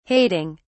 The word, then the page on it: hating: British English